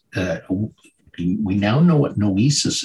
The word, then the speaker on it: noesis: Uh, am I pronouncing it properly? – Well, uh, because of the way you're pronouncing it, that's why we put the uh, that's why we put the bar over the e because it's very easy to mispronounce. Um, it's noesis, um, and that's a Greek word that means um, the arrival place of intelligence and understanding.